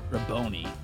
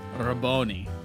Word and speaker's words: Rabboni: Rabboni. Rabboni. That's the proper pronunciation.